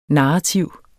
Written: NARRATIVT

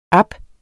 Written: ab

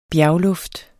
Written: bjergluft

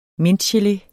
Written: mintgelé

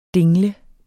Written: DINGLEDE

